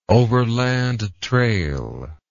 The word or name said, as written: overland trail